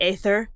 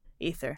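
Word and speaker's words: æther: And so we still see it in words like ether or people want to pronounce it into the aether, but it's actually just ether.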